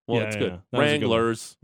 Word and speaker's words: Wranglers: There were some kids in the crowd who were going, Wranglers, and it was driving me nuts. It's not three syllables. – Okay, it's two.